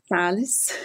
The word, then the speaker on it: Salles: How do you pronounce that in Portuguese? – Salles.